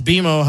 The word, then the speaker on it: BMO: It's pronounced BMO. Hello. – Right. – Oh, BMO. Not like Paisas.